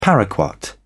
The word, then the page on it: paraquat: British English